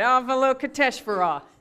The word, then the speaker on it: Avalokiteshvara: Avalokiteshvara. Okay, got it. Avalokiteshvara.